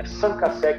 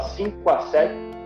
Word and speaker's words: 5àsec: Então, se a gente for pegar aqui o francês, que é da origem do nome, é Sancassé que é pronúncia. Né? Mas a gente tropicalizou aqui no Brasil, para ficar mais simples, a gente fala 5 a 7,